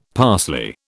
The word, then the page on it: parsley: British English